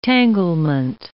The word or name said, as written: tanglement